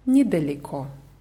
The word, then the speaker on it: недалеко: Da wird ein N je davor gesetzt und wird als nie ausgesprochen. Недалеко. – Недалеко. – Ist, ist das ein Wort? – Das ist ein Wort, ja. Die Betonung ist bei beiden Wörtern auf dem letzten O.